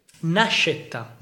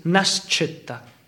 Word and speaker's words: Nascetta: Nascetta. – Nascetta. – Or there's two ways. If you say in Italian ways, Nascetta. If you say in our dialect, in Piemontese dialect, is Nascetta. – Nascetta.